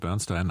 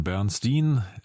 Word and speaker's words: Bernstein: Also der spricht sich nicht wie Leonard Bernstein aus, sondern Bernstein, habe ich nachgeguckt.